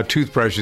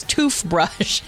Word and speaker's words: Toothbrush: So that was the first tune that was big, and now Toothbrush is expected to be the second one. Is that yes. – basically how we overview this? – You're saying it wrong. It's Toothbrush.